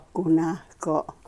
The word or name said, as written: ku nahko